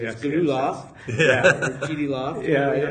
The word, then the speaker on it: GDLOFT: you guys are GD Loft.